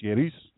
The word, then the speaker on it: Jairus: There came to him a synagogue official, Jair, which is, you know it as Jairus, which is not actually pronounced Jairus, it's Jairus, but whatever, that's just Hebrew, and it's no problem if we make up names for people, Bible people.